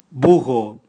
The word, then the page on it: bugo: Raga